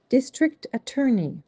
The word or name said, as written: District Attorney